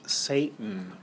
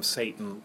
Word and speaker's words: Seyton: as Seyton, or sometimes pronounced um, Satan, uh, like the um, uh, devil, um, possibly pronounced Satan, it's not quite clear, um, that he is to Macbeth perhaps the equivalent of young Seward.